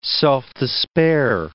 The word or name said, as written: self-despair